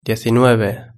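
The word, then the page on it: diecinueve: Spanish (Spain)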